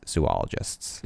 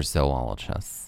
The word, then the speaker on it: zoologist: zoologists. – Is it pronounced zoologist or zoologist? – Oh, I've always said zoologist. – I think it's zoologist. Zoo and then pronounced zoologist.